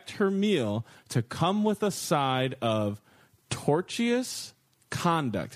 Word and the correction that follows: tortious: Am I saying it wrong? – Torturous? – There's no other R. T-O-R-T-I-O-U-S. – Tortious conduct? – Tortious?